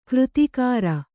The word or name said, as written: ಕೃತಿಕಾರ